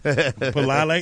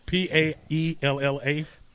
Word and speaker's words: paella: P A E L L A. – No, it's paella. – Paella. – Paella. – Where's the L's? Are they silent? – The L's are always pronounced as a Y. – Are they? – P-A-E-L-L-A.